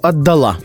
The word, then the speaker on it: отдала: Поэтому «отдал» предпочтительно и допустимо отдал, но только отдала.